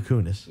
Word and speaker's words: Kunis: Dude, so I don't really know her correct way to say her name, but that's Mila Kunis. – That's man, it doesn't Kunis. – Look like me. – Is that really? Is that how you say it? – Yeah, Mila Kunis.